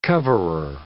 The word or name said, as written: coverer